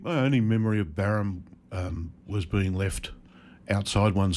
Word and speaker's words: Barham: So Barham, am I saying that right? – Barham, okay.